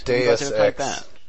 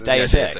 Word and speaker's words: Deus Ex: And I do really like the style of gameplay it is because I'm not sure how it kind of reminds me of Deuce Ex. – Deus Ex. – Oh, is that how you pronounce it? Deus Ex? – From, I was always called it Juice.